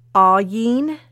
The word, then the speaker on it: Ayin: But the Hebrew letters were Ayin, and that's spelled A-Y-I-N. Ayin. Or Ayin, sorry. See? And the second letter is, it's spelled S-A-M-E-C-H.